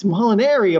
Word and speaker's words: Molinari: It's like, it's Molinari, of course, not Ari.